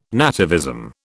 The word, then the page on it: nativism: British English